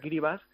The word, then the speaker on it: Kiribati: Vanuatu tiene varias islas con volcanes y tal, pero Tuvalu y Kiribati, que se pronuncia Kiribas, son muy bajitos, son los dos países que con más riesgo tienen de quedar debajo del mar.